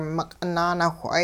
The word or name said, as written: meq'it naanawh'ay